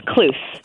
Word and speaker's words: Klus: You're close, Lisa. Thanks. It's Klus.